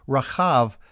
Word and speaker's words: Rahab: The woman who hid the spies and who became part of the genealogies of both David and Jesus should probably be called Rahab, because the middle letter in Hebrew is the guttural chet sound. It's like trying to clear your throat while pronouncing the letter H.